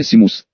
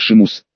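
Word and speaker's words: Seamus: На Поттер-Вики действительно написано Симус. Но тут есть маленький нюанс. Мальчик-ирландец. А согласно ирландской транскрипции имя произносится как Шимус.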